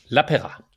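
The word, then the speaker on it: Lapeira: Spurgte du om, hvordan hans navn udtales? – Lepeyra. Okay. Paul Lepeyra.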